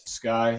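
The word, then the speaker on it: Skye: I sing, and it's spelled S-A-M. – I'm Prince. It's P-R-I-N-C-E. I play bass. – Sky, that's S-K-Y-E, and I play guitar and I sing.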